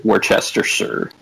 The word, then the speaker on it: Worcestershire: My dad calls it Worcestershire. Is that how you say it? – That's pretty close. I say, I don't call it Worcester sauce, which when I do, I'm making fun of Massachusetts when I do.